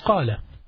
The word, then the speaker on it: qala: So the qaf, they pronounce it as a. Say, qala ala. Ya'ul means yaqul. Okay? So you need to know these different pronunciation. However, the classical Arabic is qala.